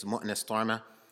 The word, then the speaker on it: Munas Tome: My name is Munas Tome. For those who are able to pronounce Arabic names, Munas Tome, and I'm an instructor here.